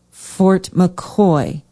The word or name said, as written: Fort McCoy